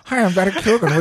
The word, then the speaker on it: Keoghan: Keoghan. Barry Keoghan.